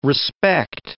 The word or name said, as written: respect